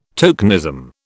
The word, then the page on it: tokenism: British English